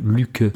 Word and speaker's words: Lykke: Luke. Ça se prononce Luke, mais ça s'écrit L-Y-K-K-E.